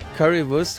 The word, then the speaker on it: Currywurst: How do you pronounce it? – Currywurst. – Currywurst.